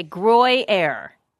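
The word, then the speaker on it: Gruyère: Gruyere? – Gruyere. – I can't even say it. My tongue. – Like groin? Like Gruyere. – Okay, Gruyere. – Yeah. – Is that right? – Gruyere.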